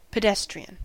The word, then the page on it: pedestrian: American English; [pə.ˈdɛs.tɹi.ən]; /pɪ.ˈdɛs.tɹɪən/; /pəˈdɛstˑɹiˑən/